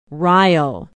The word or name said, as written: Rio